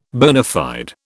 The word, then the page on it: bona fide: British English